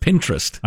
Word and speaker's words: Pinterest: The way you said it, it sounded like pederast. Donnie. – How do you say it? – Pinterest. – I don't use it. – It's like interest.